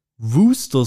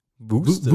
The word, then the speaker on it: Worcester: Oder auch ein absoluter Kracher. Wor Wor Worcester Sauce. – ja. ja. Kennst du, oder? Ja. – Wooster Sauce. Wooster. Wooster Sauce.